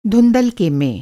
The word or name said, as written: धुँधलका